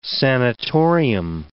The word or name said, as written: sanitorium